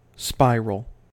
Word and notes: spiral: American English

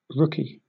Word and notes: rookie: British English

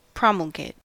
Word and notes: promulgate: American English

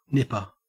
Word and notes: nipper: Australian English

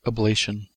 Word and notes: ablation: American English

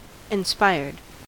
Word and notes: inspired: American English

